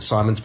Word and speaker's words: Szymons: And the name of the coffee shop has got this weird spelling of Simons. I guess you pronounce it Simons, but it's got S-Z-Y-M-O-N-S.